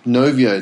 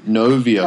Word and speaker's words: Knovio: It's called Novio. Novio. It's spelled K-N-O-V-I-O. Novio.